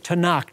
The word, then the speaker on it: Tanakh: where we get the word Tanakh,